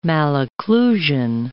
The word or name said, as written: malocclusion